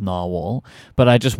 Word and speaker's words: Nahvalur: So the way you pronounce that word is Narwhal.